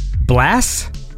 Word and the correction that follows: bless: Bless. – Like with a B sound? – Yeah.